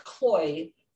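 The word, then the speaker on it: CLOi: I think this last one on the list though, it's a LG, I believe it's pronounced Cloy, guide bot that can serve as your business information desk, guide visitors to a specific location in a store.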